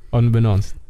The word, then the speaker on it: unbeknownst: You mispronounced it, that's all I'm saying. – Oh, did I actually? Fuck. – Unbeknownst. Like, known.